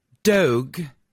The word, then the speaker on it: Dogue: Dogue, which rhymes with Vogue.